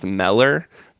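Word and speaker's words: Müller: And he said, no, it should actually be pronounced Meller, but I don't know that anybody has ever called.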